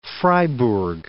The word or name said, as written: Freiburg